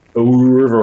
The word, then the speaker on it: oeuvre: Like I've enjoyed the whole Wilco oeuvre, or however the hell you pronounce that word. – Oeuvre, is that how it is? – That's how I say it.